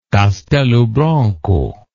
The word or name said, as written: Castelo Branco